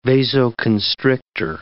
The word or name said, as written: vasoconstrictor